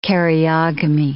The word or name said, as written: karyogamy